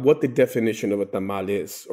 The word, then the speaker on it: tamal: Well, there's a little bit of misunderstanding as far as how they're pronounced and how they're spelled. Because if you go to certain places in Central America and South America, it's just simply tamal without the E.